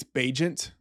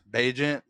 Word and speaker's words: Bagent: How do you say the Bears quarterback's last name? Bagnet? – I think it's Bagent. – Bagent. – Like he's a Bagent of chaos.